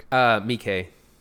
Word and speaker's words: Miike: Miike.